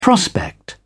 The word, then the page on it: prospect: British English